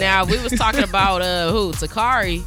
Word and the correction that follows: Takara: Did I say her name right? – I'm married. – Well, of course, but I'm saying to hop in the conversation. That's crazy. – Takara Jones, you talking about her? – Yeah.